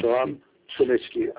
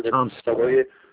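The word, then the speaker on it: Chomsky: تلفظ میکنید شوام شومسکی؟ – چامسکی. – بله. – نوام چامسکی.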